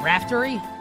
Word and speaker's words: Raftery: Ian Eagle and Bill Rafferty with the call this weekend. Raftery? I pronounced it incorrectly.